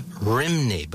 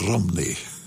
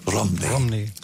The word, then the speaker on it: Rhymney: We knew it for years, or we called it the Bells of Rimney, but that's not accurate, is it? – That's how it's spelt. R-H-Y-M-N-E-Y. But there they pronounce it more like Rumney. Rumney.